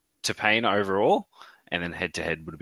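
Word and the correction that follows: Tapine: Do you mean Tapene? – Tapene, sorry. Butchered it.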